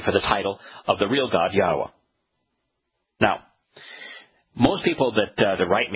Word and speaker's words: Yahweh: In other words, Yahweh, Yod-Heh-Wah-Heh, is always Yahweh. That's how it's pronounced no matter what language you're using.